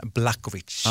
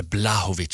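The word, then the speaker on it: Błachowicz: som hette Jan Blachowicz. – Ja, precis. Eller Blahovic, så Blahovic, jag tror de säger.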